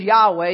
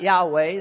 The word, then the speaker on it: Yahweh: But the thing is, no one really is sure how to pronounce this name of God. I am is how it comes into the English. I am who I am. But nobody is really sure how to pronounce it. And really, other than Yod Heh Vav Heh, the best we can do is Yahweh.